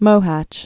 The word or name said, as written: Mohacs